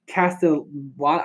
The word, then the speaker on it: Castellanos: And then for their run support, they have Votto, Moustakas, Suarez, Winker, Senzel, and Nick Castellanos.